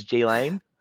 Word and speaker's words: Ghislaine: This is silent in her name. As if you aren't aware of how to pronounce it, it is Ghislaine.